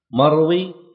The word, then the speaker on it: مروي: مروي يقال فيها كما سبق بسكون الياء، سكون الياء، فالياء هنا تكتب خطا لكن لا تنطق لفظا.